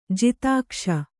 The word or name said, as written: ಜಾತಿಕೋಶ